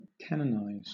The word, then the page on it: canonize: British English